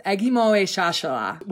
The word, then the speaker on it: Ekgmowechashala: Uh, but I have been taught that it is pronounced Ekgmowechashala, which is a Native American Sioux word.